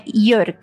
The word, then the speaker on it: Jörg: Como é que fala esse J aí? – É o J no alemão, mas sempre som de I. Então, é Jörg.